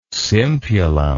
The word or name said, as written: simpulum